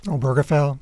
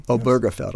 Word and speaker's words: Obergefell: So now let's look at, and I'm going to mispronounce it, Oberfeld the gay marriage case. – Obergefell. – Obergefell. Yes. I never get it right.